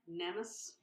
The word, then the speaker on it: Nemes: Most famously is the Nemes. Am I saying it right? Nemes crown? – Nemes. – Nemes. N-E-M-E-S.